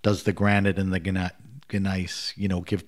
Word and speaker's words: gneiss: The soil is mostly granite, and how do you pronounce it? Gneiss, yes.